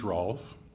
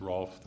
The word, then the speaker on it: Rolf: My name is Rolf Duvick. That's Rolf that rhymes with golf.